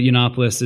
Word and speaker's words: Yiannopoulos: But Yiannopoulos is the correct pronunciation of my very Greek last name.